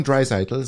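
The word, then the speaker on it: Draisaitl: Heißt der jetzt Leon? Leon Dreiseitel, so wird er ausgesprochen von den von den äh, Kanadiern im Fernsehen okay.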